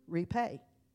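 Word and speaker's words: repay (ῥιπή): Y'all remember a few months ago, I told you that that word twinkling, the Greek, you pronounce it repay.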